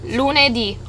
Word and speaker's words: lunedì: Monday, lunedì.